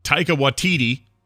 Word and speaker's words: Taika Waititi: breaking news: uh, Taika Waititi mm-hmm. who I said his name correctly right then. You did, yes. Well done, sir.